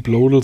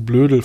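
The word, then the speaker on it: Bloedel: Ich finde es gut, dass du Blodel sagst. Ich habe echt überlegt, wie man das ausspricht, weil OE wird ja zu Blödel verleiten und das wollte ich.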